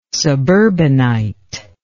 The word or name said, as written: suburbanite